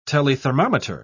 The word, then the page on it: telethermometer: British English